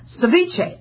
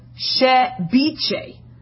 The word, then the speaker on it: ceviche: Ceviche. I've been calling it ceviche, but apparently in South America, the V is pronounced like a B, all right? So it's ceviche.